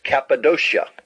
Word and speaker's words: Cappadocia: And when you see a Greek word with a kappa in it, it is pronounced a K. Like our English word cap, C-A-P, cap, k sound. And the Bible has a word, the name of a place, Cappadocia, k.